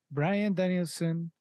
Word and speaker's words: Bryan Danielson: Bryan Danielson.